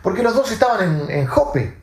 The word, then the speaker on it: Jope: Eh, Jope eh, se escribe e, J-O-P-E, Jope.